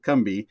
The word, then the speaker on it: Combahee: It's spelled, it should be like Combahee, but the locals there pronounce it Combi.